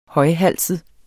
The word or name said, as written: højhalset